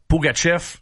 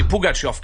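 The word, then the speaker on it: Pugachev: Ouais, puis pour répondre à ta question sur la prononciation des joueurs russes, quand on tourne ça à la Pougatchev, c'est tout simplement une tournure de phrase ou une prononciation américanisée. Parce que c'est Pougatchev qu'il faudrait dire.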